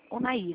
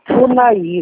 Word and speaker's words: Unaí: É Unaí ou Unaí? Unaí, né? – Unaí, isso. – Estou falando certo.